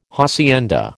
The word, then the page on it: hacienda: American English